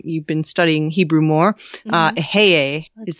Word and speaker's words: Eheieh: E-H-E-I-E-H. – Oh, yeah. – Ehie? Is that how you say it? – Ehie? That's how I say it when it's one of the divine names.